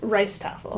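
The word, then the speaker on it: rijsttafel: And then you also, sometimes at the Borough, you have, and I'm not going to say this properly, you have an event called the Ridgestaffel? – Rice Staffel. – I tried. Oh, I almost succeeded.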